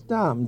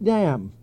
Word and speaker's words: Dahm: And the Luxembourg pronunciation is Dom, D-A-H-M. But once in a while say, well, there's Nick Dam. Or someone pronounce it Dam.